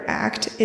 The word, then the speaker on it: ACT: From Wikipedia's article on ACT, Acceptance and Commitment Therapy, ACT, typically pronounced as the word ACT, is a form of psychotherapy, as well as a branch of clinical behavior analysis.